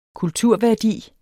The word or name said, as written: kulturværdi